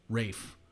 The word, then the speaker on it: Ralph: Well, his name's Rafe, but it's pronounced. It's spelled R-A-L-P-H.